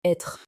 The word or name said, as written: être